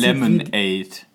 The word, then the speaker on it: Lemonade: Also wird ja geschrieben von Lemonade. Also ausgesprochen im Prinzip. – Lemonade. – Genau, also ausgesprochen wie halt die normale Limonade, aber anders geschrieben.